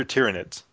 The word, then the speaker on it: Tyranids: So you can play as the Space Marines, the Orcs, the Eldar, the Tyranids. Is it Tyranids or Tyranids? – Tyranids. – Tyranids. – I've actually heard it multiple ways, but... So pronounce it however you like.